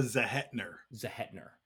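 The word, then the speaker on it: Zehetner: I think Nora, how do you pronounce her last name, Zethron or ever. I think she does a very good job in the movie. – Nora Zahetner.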